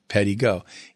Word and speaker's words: Pedego: The name Pedego was created by us, and it basically is pedal, add a little bit of E, and then you go. So it's Pedego. Pedego.